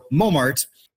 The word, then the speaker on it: Montmartre: So we have that nasal on in mon, and we don't have that T. So the T was lost just because it was never pronounced to begin with, but that on became nasal, so we ended up with mo, right? With the maltre, that R-E at the end is hard to pronounce. We don't have those type of final clusters in English. So what we did with this is dropped it off. So we ended up with mart. So Montmartre.